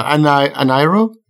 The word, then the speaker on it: Ahnayro: So we have this game that we played for today called Ahnayro. – Don't ask me to say it. – Say it. – I still can't say it. I can say it now. Ahnayro? – That's how I say it.